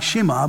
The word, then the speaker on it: Shema: It's spelled S-H-E-M-A. It looks like Shema, but it's pronounced Shema.